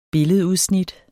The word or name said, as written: billedudsnit